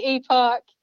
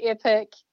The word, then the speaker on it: epoch: I say epoch, Americans say epic, so it's tomato, tomato.